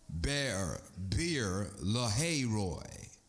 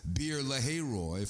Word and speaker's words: Beer Lahairoi: Now Isaac had come from Beer Lahairoi. That's how it's pronounced. Beer Lahairoi, for he was living in the Negev.